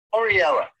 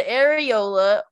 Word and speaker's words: areola: There's apparently two pronunciations. There's the areola, or there's, how do you say it? – Areola. – Areola. – Areola. – Well, I said the first one, Oriella. – No. It sounds like you're saying Oreo.